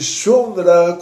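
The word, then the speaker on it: Shundra (שונרא): No lugar onde chamam o gato em aramaico de Shundra, com U, quer dizer que vai acontecer um Shinuira, vai acontecer uma mudança para mal.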